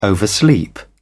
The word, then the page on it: oversleep: British English